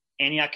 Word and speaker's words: Antioch: Andy is the lead pastor at Antioch Community Church. Did I say that correctly? – Yep. – Antioch Community Church, which has a whole nother story that's awesome as well.